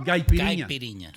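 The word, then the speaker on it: caipirinha: Gaipiriña. – Dude, that's a hard word, bro. Gaipiriñas. – No. – Pronuncia piriña. – N-H para el brasileiro. Ñ. Gaipiriña.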